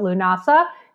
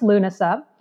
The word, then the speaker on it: Lúnasa: And a lot of times in the United States, I've heard people mispronounce it. They called it Lúnasa. It's actually pronounced Lúnasa.